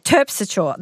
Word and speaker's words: Terpsichore: The muse, Terpsichore, Terpsichore. That's the correct pronunciation.